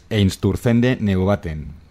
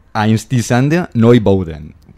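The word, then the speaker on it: Einstürzende Neubauten: Para los que somos castellanoparlantes, Einsturzende Neubauten. Para los... – Yo lo hubiera dicho así, exactamente. – Ahí en Alemania, voy a intentarlo, es difícil, ¿eh? Einstisande Neubauten.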